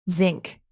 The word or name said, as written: zinc